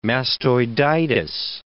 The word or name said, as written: mastoiditis